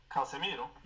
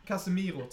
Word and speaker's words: Casemiro: Casmero. Eh, spanska inlånare spelaren. Eh, Casemiro. – Casemiro, tack så mycket. Jag kan inte uttala namnet. – Casemiro.